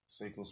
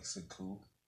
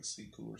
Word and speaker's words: Sekou: Seiko Smith, COVID nineteen. Wow. I, I say his name wrong. I think it's like Sekou. I think it's like Sekou or something like that.